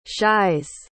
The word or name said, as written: shies